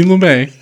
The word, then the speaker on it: Lumet: And that it's pronounced Sidney Lumet.